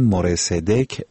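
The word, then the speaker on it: مرصدک (מורה צדק): سیامک مرصدق که نامش در زبان عبری مرصدک به معنای آموزگار عدالت تلفظ می شود 2مین دوره است که نمایندگی کلیمیان در مجلس را بر عهده دارد.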